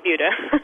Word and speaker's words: Buda: because your city is spelled B-U-D-A. – Yes, it's a very common debate if it's Buddha or Buddha, but it is Buddha.